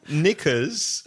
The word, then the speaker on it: knickers: Knickers, silent K.